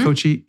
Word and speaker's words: Cocchi: I used Kochi, which looks like it's spelled like Kochi. We want to say Kochi. It's Kochi.